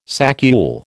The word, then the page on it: saccule: American English